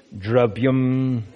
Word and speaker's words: dravyam: By the agency Suvrata, O good soul, tut that, Eva, very, he, certainly, amayam, disease, dravyam, and notice that I'm, I'm pronouncing these two A's in dravyam. I'm not saying dravyam. No, I'm not saying that. Thank you very much, Sam. Good, good example. All right, so this when the a does not have the line over, it's pronounced as a u, as in but. It, uh, we call that a schwa, a.